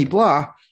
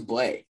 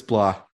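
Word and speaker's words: Blais: To get Sammy Blah, who again, I'll say it like Barkley Goodrow. – I thought it was Blay. – It's Blah.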